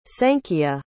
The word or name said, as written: sanchia